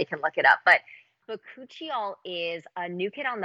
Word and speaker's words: bakuchiol: Ooh, bakuchiol.